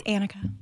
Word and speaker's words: Annika: I would say 9 times out of 10, I run into it, which now I just automatically correct. I'm just like, it's Annika. It's Annika.